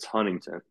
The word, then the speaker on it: Huntington: Like if any of you like start talking about, don't call it Huntington. It's Huntington. that T is silent.